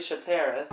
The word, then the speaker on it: Charteris: What is it? Charteris? – Yeah. Created by author Leslie Charteris. – By Leslie Charteris.